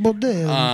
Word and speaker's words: Baldelli: Baldelli.